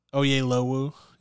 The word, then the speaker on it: Oyelowo: And then Oyelowo, yeah.